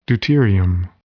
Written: deuterium

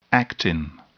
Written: actin

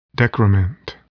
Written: decrement

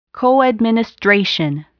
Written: coadministration

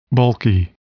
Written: bulky

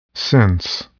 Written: sense

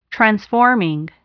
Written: transforming

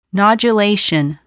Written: nodulation

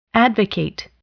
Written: advocate

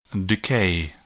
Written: decay

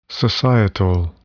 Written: societal

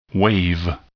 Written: wave

